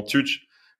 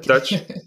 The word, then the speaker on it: Tutch: Donc ça s'écrit T-U-T-C-H. Donc euh, certains d'entre vous diront Touch, il faut dire touch, ok.